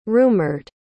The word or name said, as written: rumored